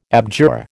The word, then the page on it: abjure: American English